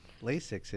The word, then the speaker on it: LASIK: What is, am I saying it right? Is it LASIKs? – LASIK. – LASIK is what you give.